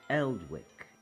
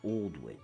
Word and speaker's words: Aldwych: or Eldwick in the local dialect, which we today pronounce as Aldwych.